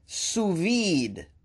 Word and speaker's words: sous-vide: Susvad? Hold on. Okay, I never seen that word before. I looked it up and figured out how to pronounce it. Sous-vide.